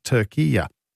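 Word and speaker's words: Türkiye: It's turkey-ya. That's how you say it now.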